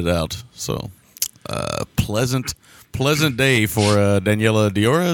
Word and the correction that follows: Daniela Doria: Is that how you say her name? – Uh, Dan- I believe Dan- Daniela so Diora. Oh, Dan- uh, Daniela Doria. Yeah, Daniela Doria. There you go, Doria, not Diora.